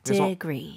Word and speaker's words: degree: 스펠링은 D-E로 시작하지만 D-I처럼 생각하시면 좋을 것 같아요. – 네. – Degree.